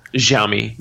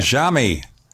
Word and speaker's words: Xiaomi: Xiaomi, which, is that the way you pronounce it, the Chinese manufacturer? – Xiaomi. Yeah. – Xiaomi.